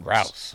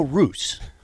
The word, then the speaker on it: Rouse: I was guessing Roos, but if I'm guessing it, it's usually wrong. – But this is no Roos.